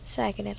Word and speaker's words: psychedelic: Psychedelic. – Zeg maar van zeiken.